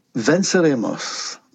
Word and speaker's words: Venceremos: Venceremos.